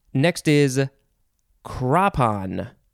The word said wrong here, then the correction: crepon: Is it crapon? Crapon, but it's spelled C-R-E-P-O-N. I'm trying to figure out the, I'm misreading it. It is crepon.